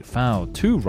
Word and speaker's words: V2: Within a few years, he would also go on to invent the infamous V2 rocket, or pronounced in German, the V2 rocket, that stunned the Allies.